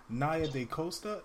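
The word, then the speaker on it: Nia DaCosta: Some of the stuff we're going over, we're going to be talking about Naya Da Costa. Did I say her name right? – Yes, you did.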